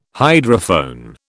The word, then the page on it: hydrophone: British English